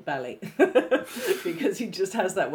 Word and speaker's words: Bali: I often wonder about how well-travelled or how knowledgeable Tom Baker actually is, because quite often he says words wrongly, like pronounce them wrongly, and the one in this episode was, it was a trick I picked up from the Firewalkers of Bali, rather than Bali.